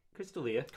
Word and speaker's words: D'Elia: Is that how you pronounce it? Is it Chris D'Elia or Chris D'Elia? – Yeah, Chris D'Elia. – Chris D'Elia, right.